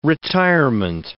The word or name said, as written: retirement